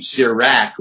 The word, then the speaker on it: Sirach: You hear that even in the name, Sirach. We pronounce it with that ah sound instead of the ah that you would get from a Hebrew word, Sirach.